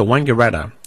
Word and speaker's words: Wangaratta: Historically, there's been disagreement about whether the first syllable in Wangaratta should be pronounced Wong or Wang. The Wong pronunciation was never used by a majority of the population and never received any wide use outside the district. It was officially disavowed by the Wangaratta City Council's declaration in favour of the Wang pronunciation in the 1980s.